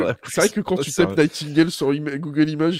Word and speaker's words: Nightingale: Il s'agit de Nike. Euh, euh, ah, Nightingale. Nightingale, merci. J'arrivais pas du tout à le prononcer.